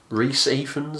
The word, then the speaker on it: Rhys Ifans: But anyway, the film is set at the Elizabethan court during the reign of Elizabeth I, played by Vanessa Redgrave here, and is it Rhys Ifans? Is that the correct pronunciation? – Yeah, that's right.